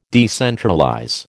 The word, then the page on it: decentralize: American English